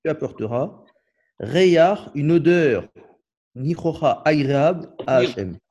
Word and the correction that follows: Nihroach: Quand tu as un patachet à la fin d'un mot en hébreu, ça se prononce ach. – Ach. Nihroach.